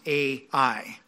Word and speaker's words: Ai: And I'm going to pronounce that word, that name of the city, Ai, just as it sounds, Ai. Some people call it A, but we can say Ai also.